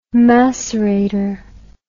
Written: macerater

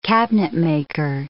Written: cabinetmaker

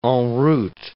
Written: en route

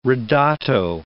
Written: ridotto

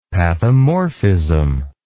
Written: pathomorphism

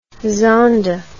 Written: zonda

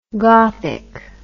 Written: gothick

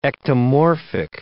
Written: ectomorphic

